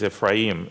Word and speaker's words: Ephraim: Wow, someone said it in with the Hebrew pronunciation. They're very good. It is Ephraim in Hebrew. His name is Ephraim.